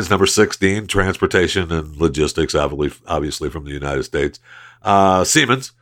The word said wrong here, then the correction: Siemens: I think that's how you pronounce that right. I better make sure I pronounce that right. Because I see their buildings all over when traveling, and I always, you know, think to myself, I wonder how you pronounce that exactly. – Siemens. – That's what I thought. That's what I said.